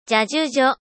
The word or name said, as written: ジャジュジョ